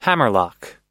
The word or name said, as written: hammerlock